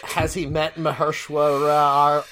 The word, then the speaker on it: Mahershala: No, there's no W in there. – Okay. Mahershala.